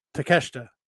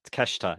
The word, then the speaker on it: Takeshita: It's not Takeshita. Takeshita. No, but do you know what I mean? If you, that's how people say it. If you pronounce it how it's written, it's the same for Yamashita.